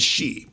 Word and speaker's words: Xi: His last name is Xi, President Xi, like S-H-E-E. But a large number of commentators, people with PhDs, generals and admirals who come on TV, they call him G, like Gee Whiz. And there are several other possibilities, because X-I, how do you know how to pronounce that?